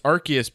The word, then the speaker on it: Arceus: Because this for me, like, any time in the past that you've said, it's Arceus because this is how you, like, pronounce the name and this is what...